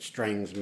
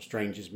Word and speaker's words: Strange's: Except if you accept that Strang's men, or Strange's men, however you want to pronounce it, were the next iteration of Leicester's men, yes.